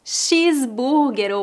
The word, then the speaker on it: cheeseburger: Cheeseburger ou hambúrguer. Em português, a letra H é muda, por isso dizemos hambúrguer. E o CH é pronunciado como SH, por isso dizemos cheesecake e cheeseburger.